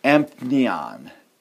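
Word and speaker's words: empneon (ἐμπνέων): It's empneon. Empneon. That's how you pronounce it.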